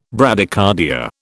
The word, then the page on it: Bradycardia: British English